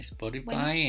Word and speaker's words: Spotify: Eh, denos like, este, sigan también en, síganos también en Spotify, ¿sí lo dije bien? – Sí, Spotify, en...